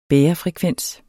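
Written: bærefrekvens

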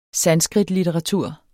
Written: sanskritlitteratur

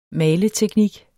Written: maleteknik

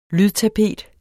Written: lydtapet

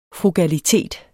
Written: frugalitet

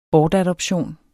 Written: bortadoption

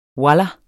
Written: wallah

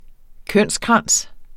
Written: kønskrans

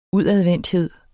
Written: udadvendthed

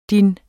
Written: dit

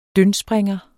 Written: dyndspringer

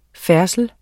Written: færdsel

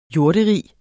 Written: jorderige

